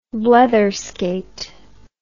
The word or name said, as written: bletherskate